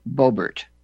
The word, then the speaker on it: Boebert: We've got a pronunciation here. Boebert. Boebert. B-O-H. The phonetical. B-O-H-B-A-R-T.